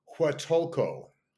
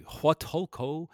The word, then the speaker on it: Huatulco: In Huatulco. Thank you. Huatulco.